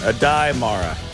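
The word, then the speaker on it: Aday Mara: You know, I'll just go with the commissioner's pronunciation. Adai Mara. Sounds good to me.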